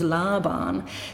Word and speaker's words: Laban: Interesting that we start with Laban, and I'm going to transfer it into the Hungarian pronunciation, which is Laban. So being Hungarian, Laban. Here we go.